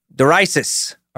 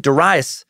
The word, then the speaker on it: Darius: When I first read Darius, it's spelled D-A-R-I-U-S, like Darius, and which reminded me of Daryl, which made me think of a king who was not regal.